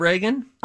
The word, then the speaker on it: Regan: There's an R-E-G-A-N, and then there's an R-E-A-G-A-N. Apparently, they're both pronounced Reagan. – Yes. – Not Regan. – Fun fact. Okay. I have a neighbor boy named Reagan, R-E-A-G-A-N, and I have a neighbor girl named Regan, spelled the same exact way.